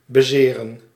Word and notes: bezeren: /bəˈ.zeː.rə(n)/; [bəˈ.zɪː.rə(n)]

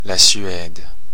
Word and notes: Sweden: /ˈswi.dən/

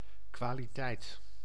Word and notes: quality: /ˈkwɒl.ɪ.ti/; /ˈkwɑl.ɪ.ti/

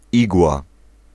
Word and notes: igła: [ˈiɡwa]

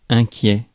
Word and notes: inquiet: France; /ɛ̃.kjɛ/

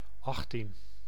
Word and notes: achttien: /ˈɑx.tin/; /aχt.tiːn/

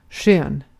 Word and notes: skön: /ɧœːn/

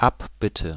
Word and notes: amende: [a.mɑ̃d]; France

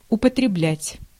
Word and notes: употреблять: /ʊpətrʲɪbˈlʲætʲ/; upotrebljat

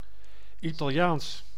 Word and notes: italiensk: /ɪ.ta.lɪˈeːnsk/

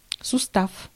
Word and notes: сустав: /sʊˈstaf/; sustav

